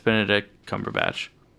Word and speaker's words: Cumberbatch: How do you say his name? I'm just kidding. It's Benedict Cumberbatch.